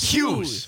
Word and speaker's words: Hughes: Hughes. – Hughes. – Yeah. – Like Hugh Hefner.